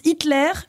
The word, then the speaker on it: Hitler: Quel célèbre dictateur allemand de la première moitié du XXe siècle a dit, je cite, ça se prononce Hitler, mais attention, il n'a pas d'accent sur le E.